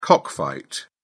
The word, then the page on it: cockfight: British English